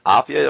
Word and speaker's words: Apia: You debut over in Samoa in Apia, am I pronouncing that correctly? – Yeah, that's right.